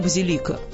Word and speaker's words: базилика: Говорите «базилика». Тем более, что так проще запоминать. Есть растение – базилик, а есть постройка. Базилика. И здесь и там ударение на второе «и».